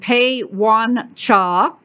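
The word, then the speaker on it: Pei-Yuan Chia: He is the Francis and Pei Wan Cha. Did I say that right, Pete? – Sure did.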